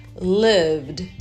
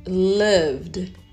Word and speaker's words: lived: Então você vai pronunciar com D. Lived, lived. Lembra que não vai pronunciar o som do E. O E aí vai ficar silent.